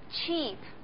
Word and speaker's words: cheap: cheap，它的意思是便宜的。 ch 后面的 e 这个发音呢是长音，它后边两个圆点表示呢它需要发的足够长。